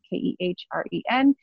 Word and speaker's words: Kehren: My last name is spelled K E H R E N.